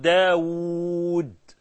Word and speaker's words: داود: قال له سرق واو مولانا، ما هو سي داود احنا بنكتبها بواو واحدة وهي بتتنطق أو بالأصل تتنطق باتنين واو، لما تقول داود دال ألف واو واو دال، طاووس طا ألف واو واو سين ها، لكن احنا بنكتبها داود طاووس بواو واحده.